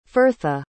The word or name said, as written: firtha